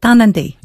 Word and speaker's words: Tarnanthi: It's pronounced Tarnanthi, is that right? – Yeah, Tarnanthi.